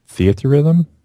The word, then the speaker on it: Theatrhythm: Final Fantasy Theatrhythm. – Mm-hmm. Okay, Curtain Call Edition, which is I guess. – So I have this as well, and I love Theatrhythm, the first one.